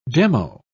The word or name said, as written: demo